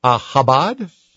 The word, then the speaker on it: Chabad: Chabad? – Wow.